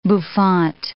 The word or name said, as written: bouffant